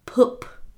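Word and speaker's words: Puup: And it's spelled P-U-U-P, I believe. And it's spelled, or pronounced poop. So kind of like poop. – Yeah. – But not. – But it looks like pup. – But it looks like pup, like a puppy.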